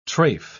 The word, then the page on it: tref: British English